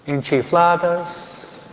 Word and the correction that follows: enchiladas: ¿cómo? O enchiladas, sí. Siempre lo digo mal.